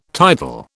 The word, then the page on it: tidal: British English